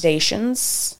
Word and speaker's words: Dacian: Is Dacians, D-A-C-I-A-N. – Dacian. – Yeah. Which was a Thracian tribe. – Spell it. – T-H-R-A-C-I-A-N.